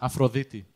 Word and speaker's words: Aphrodite: Aphrodite.